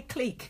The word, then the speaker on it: clique: Yeah, say clique because that's how it's pronounced, you fucking moron.